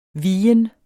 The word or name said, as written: vigen